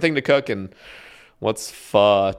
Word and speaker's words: pho: Why do you like pho? So how do you pronounce it? Pho? – Yeah, pho. – Is it spelled P-H-O? – P-H-O. – Oh, interesting. Pho.